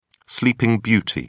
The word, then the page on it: sleeping beauty: British English